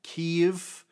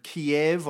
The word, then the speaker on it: Kyiv: So then there were huge protests, the Maiden protests, the Euromaidan protests, as they're variously known, in Kyiv. Kiev, I used to pronounce it, but now I hear it's more often pronounced Kyiv.